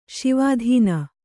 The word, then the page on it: ಶಿವಾಧೀನ: śivādhīna; Kannada